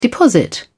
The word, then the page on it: deposit: British English